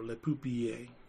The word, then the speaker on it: Le Pupille: It's the same letter pronunciations as in Spanish. – Is it? – In Italian. – I didn't know that. Well, in that case, Le Pupille. Oh, it's a double L, so Le Pupille.